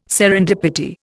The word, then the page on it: serendipity: Indian